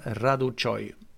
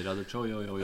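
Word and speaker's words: Răducioiu: Det var en jävla diskussion om hur man uttalade hans namn och hur man uttalade Florian Raducoy. Vilket inte var rätt. Utan... – Som Arne säger, Raducoy.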